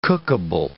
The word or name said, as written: cookable